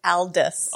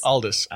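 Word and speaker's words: Aldous: It's not Albus like Dumbledore. It's Aldus. – Aldus.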